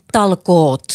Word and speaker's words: talkoot: And if we could encourage the rest of the world to have this sense of Finnish Talko. How do you say that? – Talkoot. – Talkoot. – There's a T in the end.